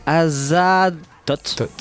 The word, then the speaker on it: Azaztoth: Primate, juste Primat, je primate, On vient de s'écouter un premier morceau qui s'intitule donc, alors si je prononce bien, Azaz, Azat, oh j'arrive pas, Azad Tot. – c'est ça.